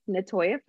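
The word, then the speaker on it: Natoia: So I am most active on Facebook under my full name, Natoya Franklin. And I'll spell it because, you know, people don't know how to spell Natoya sometimes. So it's N-A-T-O-I-A, Natoya Franklin.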